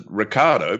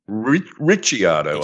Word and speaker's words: Ricciardo: I normally pronounce it Ricardo because that's the way he does, but I have been told that it should actually be Ricciardo, I believe.